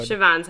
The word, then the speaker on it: Siobhan: Siobhan's how that's pronounced. Okay. I mean, that's not what it looks like, but that's how that's pronounced.